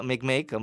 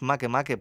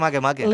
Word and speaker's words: Makemake: Makemake en anglès i no, és Makemake perquè té nom hawaià. – Ah, mira. – En hawaià es pronuncia Makemake.